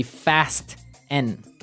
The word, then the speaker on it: fasten: It's spelt F-A-S-T-E-N. – Right, so it would be fasten. – No. – No, right? – Fasten. – Fasten. The fasten. T kind of becomes silent.